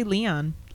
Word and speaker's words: Leon: And you said it right. I don't even say it like that. – How do you say it? – I just say Leon.